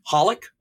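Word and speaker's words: xxxHOLiC: Or one of the ones on my list that I didn't use is also, it's pronounced Holic, but there's three unnecessary X's in front of it.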